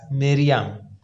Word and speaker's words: مریم: تلفظ مریم در زبان عبرانی با کسره میم هست، مریم گفته میشه، و این اسم قبلا میان بنی اسرائیل وجود داشته.